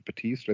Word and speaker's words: Bautista: And a lot of the people, like the actor that plays Drax, Dave Bautista, I think is how you say his name.